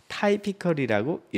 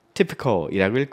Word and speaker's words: typical: 읽을 때는 typical이라고 읽으면 안 돼요. typical이라고 읽고 뜻은 전형적인, 일반적인 타입이 있는 이란 뜻으로 외워주시면 되겠습니다.